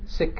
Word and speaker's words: Sikh: Sikh is actually the correct pronunciation.